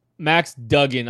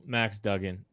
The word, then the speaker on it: Duggan: Max Duggan, I'm sorry. You said it right. I said it wrong earlier.